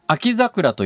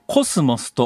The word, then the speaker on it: 秋桜: ころがですね秋の桜と書いて、うん、秋桜と読まずにコスモスと読ますようになったのは、うん、このヒット曲以来なんです。